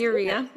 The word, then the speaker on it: urea: Is that how you say it, urea or urea? – Mm-hmm. Yeah, that's urea. Cool.